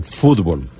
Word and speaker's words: fútbol: La palabra fútbol escrita en español se incorporó al diccionario de la lengua española en 1926 pero con tilde en la U, fútbol. Fútbol, claro. Tal como la pronunciamos en Colombia.